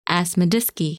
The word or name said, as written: Asmidiske